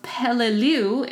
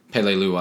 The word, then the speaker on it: Peleliu: In June 44, the Battle of Peleliu in the Palau group. – Yeah. – Did I pronounce that right? – Palau, yes. Peleliu Islands? – Yeah.